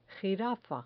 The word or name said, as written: jirafa